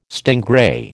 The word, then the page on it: stingray: American English